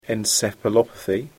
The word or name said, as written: encephalopathy